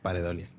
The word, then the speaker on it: pareidolia: Y la neta, el 90% de las caras es pareidolia. – Okay. – Pareidolia. Se me olvida pronunciar esa I. – La I. Pareidolia.